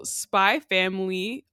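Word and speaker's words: Spy × Family: So Spy Family is stylized as Spy X Family, but you know, with Japanese manga and TV shows, the X is silent in between. So people who say Hunter X Hunter is Hunter Hunter.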